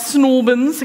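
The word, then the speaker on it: St Albans: Snorbins, I got to say it like that.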